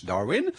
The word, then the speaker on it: Darwin: Ecco, stanno fioccando gli sms, di uno possiamo facilmente tener conto, perché ci dice: caro conduttore, Darwin era inglese, e allora perché pronunciare il suo cognome alla tedesca? Facciamo venia, naturalmente, d'ora in poi parleremo di Charles Darwin, ed è di questo che stiamo parlando.